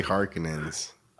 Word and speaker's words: Harkonnen: They say Harkonnens. Uh huh. And in the other movie, they say Harkonnens. I like Harkonnens better. I think, mm. from what I've looked up, is the author's true pronunciation is Harkonnen.